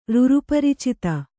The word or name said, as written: ರುರುಪರಿಚಿತ